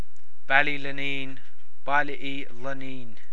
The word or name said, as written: Ballylaneen